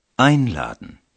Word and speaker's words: Einladen: به تلفظ فعل Einladen یعنی دعوت کردن گوش کرده و به پیشوند Ein در این فعل توجه کنید. این پیشوند به هنگام تلفظ مورد تأکید قرار می گیرد چون که این پیشوند معنی اصلی این فعل را بیان می کند.